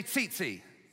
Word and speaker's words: tzitzit: It's called a tzitzit in Hebrew. Say tzitzit. It's spelled T-Z-I-T-Z-I-T. So if you're from Dillon, you say T-Z-Z-Z, but I don't know how you say it, okay?